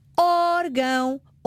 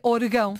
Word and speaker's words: órgão: Em órgão, acento no O. Orégãos, acento no E. Porquê? Porque se eu não tiver acento, naturalmente as palavras terminadas em ão gostam de receber a sílaba tónica. Irmão. Coração. – Neste caso seria orgão. – Pois, se eu não colocar o acento sobre a vogal, a tonicidade recai no ão.